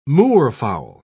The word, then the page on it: moorfowl: British English